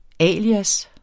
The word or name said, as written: alias